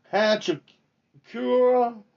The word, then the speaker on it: Hachimura: I can't say his name. Himatura from Washington just made a poster on Tobias Harris, ladies and gentlemen. Rui Hachimura is what I'm trying to pronounce, what I saw on my TV.